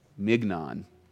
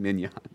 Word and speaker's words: mignon: But we want the you know, whatever your favorite food is, the filet mignon, mignon. It's spelled mignon, but it's mignon.